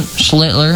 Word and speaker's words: Schlittler: Cam Schlittler, I think that's how you pronounce his last name, right? – Yeah.